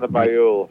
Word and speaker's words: Bayul: I'm not even sure I'm pronouncing the name right. – Oksana Bayul.